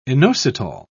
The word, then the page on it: inositol: British English